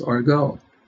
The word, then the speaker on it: argot: It could be in French, I believe it's pronounced Argot.